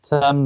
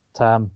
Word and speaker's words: Tam: So I just love the fact that one of the people in this match first name is Tam, like it's spelled T A M. That's how I'm saying it.